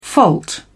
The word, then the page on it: fault: British English